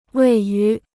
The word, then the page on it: 位于: wèi yú